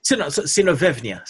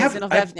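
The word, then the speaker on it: Zinovyevna: Alisa Zinoyevna Rosenbaum. – Ah, okay. – Zinovevna, sorry. – Okay, Zinovevna, yeah.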